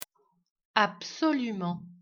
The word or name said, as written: un abri